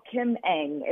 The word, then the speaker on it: Ng: You know, Kim Ng is how you pronounce her name. I kind of say it in my head, A-N-G, Kim Ng.